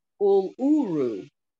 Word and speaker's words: Uluru: It's called a piece of Uru. Let me get this right. Uluru rock. Piece of Uluru.